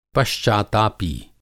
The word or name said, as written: पश्चातापी